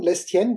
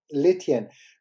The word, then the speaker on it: Lestienne: Und ich hätte schon Lestienne gesagt, aber Alexi hat mir die Ohren lang gezogen und hat gesagt, natürlich wird das S nicht ausgesprochen. Constant Lestienne.